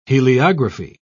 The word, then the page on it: heliography: British English